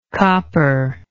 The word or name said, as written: copperish